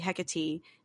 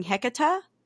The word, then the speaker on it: Hecate: I tried to explain to her, I was like, yeah, it's supposed to be Hecate. She would like not pronounce it right on purely on the basis that somebody corrected her. And so she kept going Hecata. And I'm like, yeah, okay, that can work, I guess, but I know why you're doing that.